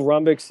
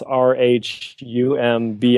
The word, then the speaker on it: Rhumbix: and so, uh, rhumb spelled like that r-h-u-m-b is actually a navy navigational term and so uh, zach and i want to do something that uh, hearken to our shared Navy heritage.